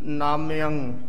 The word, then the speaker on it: नामयं: जिस तरह उच्चारण किया गया है भाई साहब जी ने इसे तरह उच्चारण संगत जी करना ए जिये अखर लगे होने करके नामयं ठामयं इसे तरह उच्चारण करना है ना दा नन्ने कन्ना ते मम्मे नू सिहारी नामयं ते इस तरह ऐडे दे उपर टिप्पी दे नाल इस दा उच्चारण है नामयं और ठामयं ये ठीक उच्चारण किया गया इसे तरह ही करना चाहिए जी